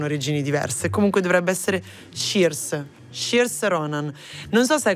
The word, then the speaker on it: Saoirse: Mi hanno detto, quanto ne so, si pronuncia Saoirse Ronan.